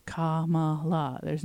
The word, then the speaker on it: Kamala: All the A's have the same sound. It's Kamala. You don't have to do anything.